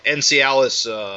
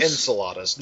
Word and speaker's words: Enceladus: Just to talk about the story a little bit, of course, this is a follow-up to, I can never pronounce it, Encialis, Enceladus? – Enceladus,